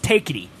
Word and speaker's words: Tecate: Tecate? – Tecate. I think casual is a big Tecate fan. – Tecate? – Yeah, it's pronounced takety. Don't you like some takety?